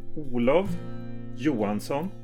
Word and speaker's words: Olav Johansson: My name in Swedish pronunciation is Olav Johansson.